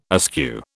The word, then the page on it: askew: British English